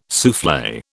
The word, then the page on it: souffle: British English